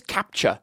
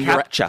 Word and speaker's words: CAPTCHA: One thing we learned about 2 weeks ago, we talked about catchphas. I don't know how to pronounce it. – Catchphas? I think it's captcha. – Captcha. – Like it's capturing captcha.